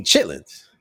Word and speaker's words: chitterlings: Oh, you mean Chitlins. Y'all saying it wrong.